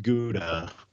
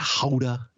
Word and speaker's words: Gouda: Now, the thing with Gouda is – everybody gets into a pissing match about how you say it. Is it Gouda? Is it Gouda? You know, it's Dutch. But just you're just doing it the American style. Just say Gouda.